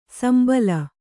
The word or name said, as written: ಶಂಬಲ